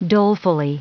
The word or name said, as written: dolefully